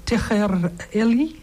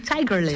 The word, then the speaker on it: Tiger Lily: Wat staat er nou? Tiger Lily? – Nee, Tiger Lily. – Tiger Lily. Ik legde de klemtoon.